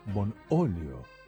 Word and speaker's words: Bonolio: Bonolio. Con acento en la O.